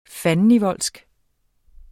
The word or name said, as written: fandenivoldsk